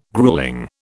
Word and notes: gruelling: British English

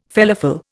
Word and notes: felafel: Indian

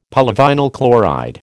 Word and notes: polyvinyl chloride: American English

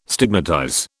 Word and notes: stigmatize: British English

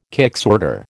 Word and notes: kicksorter: American English

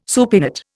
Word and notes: supinate: Indian

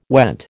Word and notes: went: American English

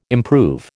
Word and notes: improve: American English

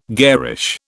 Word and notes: garish: British English

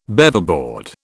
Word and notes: Beaverboard: British English

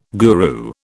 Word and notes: guru: British English

